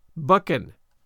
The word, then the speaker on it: Buchan: But I have to say, I always thought the author's surname is pronounced Buchan, it being of Scottish provenance.